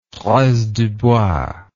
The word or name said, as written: fraise du bois